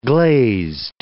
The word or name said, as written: glazed